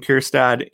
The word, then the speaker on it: Kjerstad: Heston Kjerstad, easy. Silent J there.